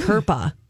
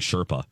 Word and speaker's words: Kirpa: I, I I keep mispronouncing her name on the TV show when it comes up on the teleprompter. – Yeah. I keep saying Sherpa.